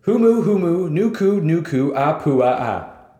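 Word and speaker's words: Humu, humu, nuku, nuku, apua, a (humuhumunukunukuāpuaʻa): So this is how you pronounce it. Humu, humu, nuku, nuku, apua, a.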